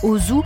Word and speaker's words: Auzoux: Au 19e siècle, le docteur Ozu, alors c'est pas le cinéaste japonais, hein, Ozu, A-U-Z-O-U-X, invente les écorchés.